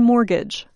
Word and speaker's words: mortgage: Because of the word's French roots, we do not pronounce the letter T in mortgage.